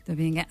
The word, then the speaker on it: Tübingen: Va estar marcada la seva formació acadèmica pel seu doctorat en teologia a la Universitat de Tübingen. Ho pronuncio bé? – Sí, més o menys. – O sigui, no. Com ho pronuncia vostè? – Tübingen.